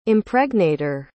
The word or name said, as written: impregnator